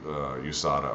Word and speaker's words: USADA: USADA or USADA, however you want to pronounce it.